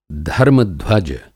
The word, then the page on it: धर्म-ध्वज: Hindi